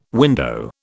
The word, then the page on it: window: British English